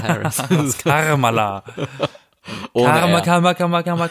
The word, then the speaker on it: Kamala: Die Vizepräsidentin, die Kamala Harris, wenn ich das jetzt richtig ausspreche, weil sie heißt ja nicht Kamala, sondern Kamala. Kama, Kamala.